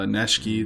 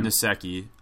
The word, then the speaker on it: Nsekhe: Uh, Neshke mm-hmm. too. I can never say his name. Nsekhe.